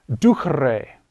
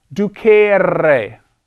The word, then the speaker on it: ducere: This is pronounced ducre. It's not ducere. Do not pronounce it that way. It's ducre. That E is short.